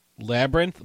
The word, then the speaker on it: Labyrinth: I'm like, "Laby something," and she's like, "Labyrinth."